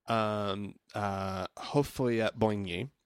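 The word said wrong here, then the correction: Houphouët-Boigny: I looked it up, and it looks like it's pronounced Ufwet Bwanyi. Bwanyi. – Ufwet Bwanyi? – Ufwet Bwanyi.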